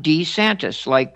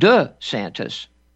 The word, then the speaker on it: DeSantis: Whenever he was asked his name, he said his name was DeSantis, Ron DeSantis, you would say it in Italy. And Then he switched it to the French pronunciation, DeSantis.